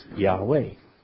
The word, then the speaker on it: Yahweh: Therefore, it stands to reason that Yahweh is pronounced Yahweh.